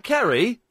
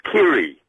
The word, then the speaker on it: Kiri: Is that Kerry? – No, he's not Kerry. It's Kiri.